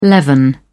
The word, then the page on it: leaven: British English